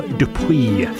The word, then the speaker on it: Dupuis: Carol Dupuis. Did you hear that silent S?